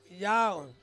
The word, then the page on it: yau: Ansus